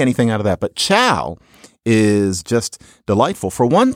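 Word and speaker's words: ciao: But one interesting thing about it is that somebody does say chow. It's spelled funny. It's like chow.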